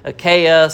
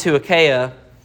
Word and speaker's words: Achaia: he travels south into Achaia, sometimes pronounced Achaia, where he ended up was ultimately the city called Corinth.